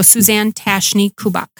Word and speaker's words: Tatchney-Kubach: You're not even getting Tashney right. Suzanne Tatchney with a soft CH. It's a French Tatchney. – I never knew that. – And a German Kubach. So Suzanne Tatchney-Kubach.